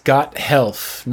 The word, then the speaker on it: Gothelf: Like, it's two words, Gothelf, not the two words that you think it is.